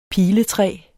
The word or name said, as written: piletræ